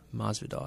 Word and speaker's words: Masvidal: Diaz is fighting Masvidal for the, I think it's Masvidal or Mavidal. Masvidal, I think it's pronounced, for the bad MF.